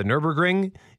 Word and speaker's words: Nürburgring: And we have confirmation from Mel that my pronunciation of the Nürburgring is correct.